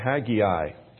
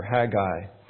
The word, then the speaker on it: Haggai: We're going to look at Haggai, or Haggai, depending on how you say it, and how many syllables you need.